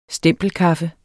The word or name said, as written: stempelkaffe